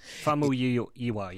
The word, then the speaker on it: Famuyiwa: The director of this episode is Rick Famuyiwa, I think is how you pronounce his last name. – Famuyiwa, yeah.